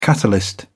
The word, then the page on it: catalyst: British English